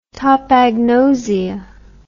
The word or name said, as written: topagnosia